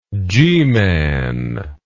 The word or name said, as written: G-man